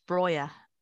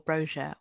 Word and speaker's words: Broja: I actually feel quite bad saying this, but it's Broja or Broja or however you would like to pronounce it, but I will be using the Albanian pronunciation. Broja, I just think I had him out of hope more than judgment.